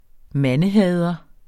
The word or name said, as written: mandehader